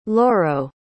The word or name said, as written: Loro